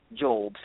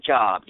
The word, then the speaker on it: Job: Right after the book of Job, some of y'all might think it's spelled Job, J-O-B, but it's called the book of Job.